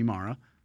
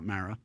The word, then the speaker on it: Mara: It's just it's like a big – it's an unusual sequence for a film where your sister, the amazing Rooney Mara – not Mara – is basically eating an entire pecan pie or pecan pie, depending on your pronunciation, by herself.